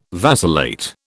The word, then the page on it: vacillate: British English